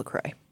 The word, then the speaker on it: LaCroix: She pronounces it like this. – I think I've been drinking LaCroix for many, many years, perhaps 20. – LaCroix. – LaCroix. It is. It's LaCroix. It's pronounced LaCroix.